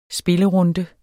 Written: spillerunde

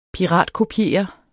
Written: piratkopiere

